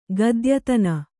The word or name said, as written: ಗದ್ಯತನ